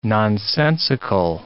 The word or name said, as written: nonsensical